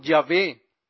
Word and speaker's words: Yahvé: En el hebreo era originalmente YHWH, que pronuncia como Yahvé, dijo a mi Yahvé.